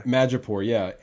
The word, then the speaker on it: Madripoor: And they also brought up a new place where they visited very briefly, Madripoor, I believe I said that correctly. – Madripoor.